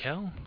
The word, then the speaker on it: Buckel: Is it Buckle or Buckel? Do you know? – I think it. – How the Rangers? – Buckel. – Buckel. Okay, I like that better.